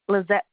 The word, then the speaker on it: Lizette: Lizette Petrovich.